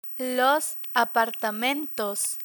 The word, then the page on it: apartamentos: spanish